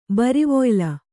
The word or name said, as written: ಬರಿವೊಯ್ಲ